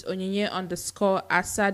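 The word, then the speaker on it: Onyinye Asa: My Instagram name is Onyinye_Asa, that's spelled O-N-Y-I-N-Y-E underscore A-S-A.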